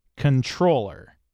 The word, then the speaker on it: comptroller: C-O-M-P-T-R-O-L-L-E-R. Pronounced controller. – Right. – So there's not so much a silent letter as two letters changing into another letter entirely. – Yes. – Very good. – The MP turned into an N.